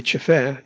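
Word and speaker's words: Tefé: He says, Radio Rural, or Radio Rural de Chefé, seems to be its new name. By the way, Chefé is spelled T-E-F-E with an acute accent on the second E.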